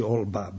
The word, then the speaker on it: Holbach: Najveći ateista je bio Holbach. I on je taj koji je, znači Olba, baron Olba, mi ga izgovaramo na nemački način, on je čovjek koji je finansirao čitavu propagandu, ono baš kako se danas radi, od vrata do vrata.